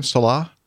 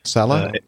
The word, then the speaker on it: Sala: Salah? – Salah.